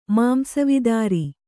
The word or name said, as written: ಮಾಂಸವಿದಾರಿ